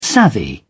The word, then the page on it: savvy: British English